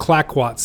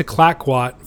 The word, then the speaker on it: Clayoquot: By mid-May, the Columbia reached Clackwat Sound. I had mispronounced it Clayoquot Sound on a past episode, and I apologize for that. Gray made it to Clackwat 4 months faster than Kendrick had done on their first voyage.